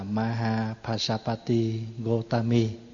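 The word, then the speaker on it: Mahāpajāpatī Gotamī: Đại chúng, tuần rồi chúng ta học về vị ni đầu tiên của Phật giáo, đó là Di Mẫu Mahabasabade. Tiếng Phạn, tiếng Pali đọc là Mahapasapati Gotami, và Trung Hoa đã dịch là Đại Ái Đạo.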